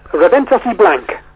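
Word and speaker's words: Raventós i Blanc: Am I saying it right? Reventos Blanc? – Reventos y Blanc. – Reventos y Blanc. – Yeah, there's an I between Reventos and Blanc.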